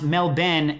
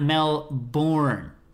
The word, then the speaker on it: Melbourne: I'm from Melbourne, Australia, which is pronounced Melbourne, not Melbourne.